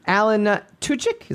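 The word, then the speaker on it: Alan Tudyk: Alan Tuchik, is that how you pronounce his name? – Yes.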